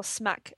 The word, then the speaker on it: SMAC: Yeah, Sarita's mac and cheese, or SMAC as it's known. This is a very drug-heavy food segment. Yeah, it's spelled S-M-A-C, as in Sarita's mac and cheese.